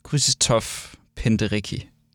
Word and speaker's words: Krzysztof Penderecki: Ja, Krzysztof Penderecki.